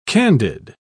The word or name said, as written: candid